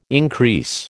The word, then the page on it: increase: American English